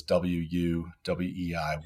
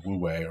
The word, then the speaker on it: Wu Wei: Uh, it's anglicized as W U W E I, Wu Wei, or pronounced Wu Wei sometimes it literally means no action, but what it actually means is effortless action.